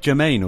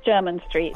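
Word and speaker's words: Jermyn Street: She's going to tell me how to pronounce German Street. Am I saying Germain or German Street? – It's German Street. – I've always called it Germain Street. Well, no wonder people give me funny looks.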